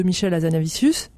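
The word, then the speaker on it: Michel Hazanavicius: C'était en 2017 de Michel Azanavicius. J'ai bien prononcé C'est ça. C'est ça, voilà.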